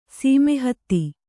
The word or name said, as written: ಸಮೀಹಿತ